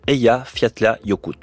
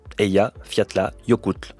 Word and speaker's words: Eyjafjallajökull: La prononciation de son nom est en soi un petit défi qui nécessite de bien détacher chaque syllabe. Eyjafjallajökull. Eyjafjallajökull.